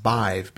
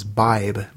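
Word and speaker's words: Badhbh: So I'll tell you, the Gaelic spelling is pronounced Nisha Mac Ishlu, and the goddess name is pronounced Bive, B-I-V-E. – Hmm. – And that's one I had always heard pronunciation was Bibe, B-I-B-E, but that's close enough.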